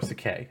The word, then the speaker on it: Sakai: I call it Pride of Sakai, or Sakai, actually, because that's how you say it.